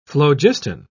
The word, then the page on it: phlogiston: British English